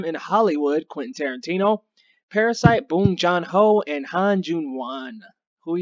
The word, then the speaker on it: Bong Joon-ho: Bong Joon-ho. Say it with me, Bong Joon-ho.